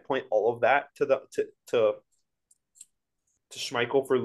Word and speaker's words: Schmeichel: May have pronounced the name wrong. – Schmeichel.